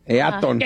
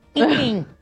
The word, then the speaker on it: Eaton: ¿De Eaton? – ¿O Eaton? – Eaton. – Él dice Eaton. Eaton. – Well, that's how you say it, Eaton.